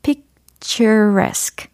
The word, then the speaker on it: picturesque: 그러면 이게 발음이 어떻게 될까요? 최종적으로 picturesque 이겁니다. picturesque, picturesque, picturesque, picturesque.